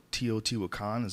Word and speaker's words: Teotihuacan: Well, the creation of the temple of Teotihuacan, is that mm-hmm.